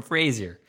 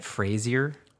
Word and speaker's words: Frasier: Is that how you say it? Frasier? – Or Frasier. – With a shh. – Just depends on Frasier. Where you're from.